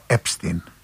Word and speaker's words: Epstein: Εδώ στην Ελλάδα λένε Epstein, γιατί κανονικά ως γερμανικής προέλευσης προφέρεται Epstein, αλλά στις ΗΠΑ που τα προφέρουν εξαμερικανισμένα προφέρεται Epstein. Και εγώ βεβαίως, εφόσον ο ίδιος προσφωνούσε τον εαυτό του Epstein, χρησιμοποιώ το Epstein και όχι το Epstein.